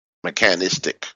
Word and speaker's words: mechanistic: And he says in the last two sentences of this chapter, the world is imaginal, not mechanist, mechanic, mechanist. I always mess that word up. But anyways, in other words, it's not mechanical. Mechanistic, excuse me, I just had to say it right.